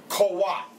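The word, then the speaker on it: koach (כֹּחַ): You pronounce it koach. Amen? – Yes. – Koach. You spell it K-O-A-C-H.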